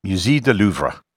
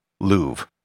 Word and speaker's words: Louvre: In French, the name would be pronounced Musée du Louvre. However, that is not how I'm going to pronounce it for the rest of this episode. I'll be using the most standard English pronunciation of the museum, Louvre. The reason for this is that this podcast is in English, and Louvre isn't how it's commonly pronounced in English.